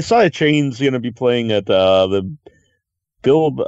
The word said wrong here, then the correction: Bilbao: It was at the Bilbao Film Festival, and then it was May 2nd.